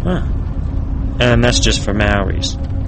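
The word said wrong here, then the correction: Māori: Say Mao like as in Chairman Mao. – Maori.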